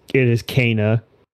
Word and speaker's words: Kena: I do know someone named Kena, and she doesn't spell it that way. There's an A in her name, so I just assume maybe it's Kena, but they do say it multiple times. It is Kena, Bridge of Spirits.